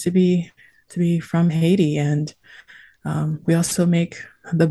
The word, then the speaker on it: Haiti: And so I think to begin, my people are the people of an island nation known as Haiti, or Haiti in the English pronunciation.